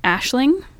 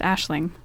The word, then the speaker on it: Aisling: you pronounce it Aisling. – How's it spelled? Xyraoinejkr. – No, Aisling. And it means dream or vision. – Oh, God, Irish is so pretty. – Yeah. But it's, yeah, pronounced Aisling.